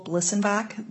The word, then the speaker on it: Blissenbach: My name is Nicole Bliesenbach. That's spelled B-L-I-S-S-E-N-B-A-C-H.